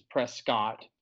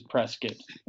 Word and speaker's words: Prescott: It's not worth me saying it properly, which is Prescott. I, you know, advert to saying it wrong, which is Prescott.